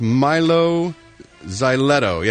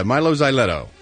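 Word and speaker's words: Mylo Xyloto: It's pronounced, according to Chris, Milo Xyloto. Yeah, Milo Xyloto.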